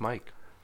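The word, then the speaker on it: Meic: Is that Mark word pronounced Mike? – Yes. – You never know that my grandmother was a Welsh speaker. – Well, it's M-E-I-C.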